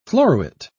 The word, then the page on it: floruit: British English